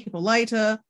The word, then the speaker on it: Hippolyta: But I guess it would be Hippolyta if her nickname is Lyta. – Yeah. – Then it makes sense that her full name would be Hippolyta with the emphasis on the end, and that's how you get the nickname.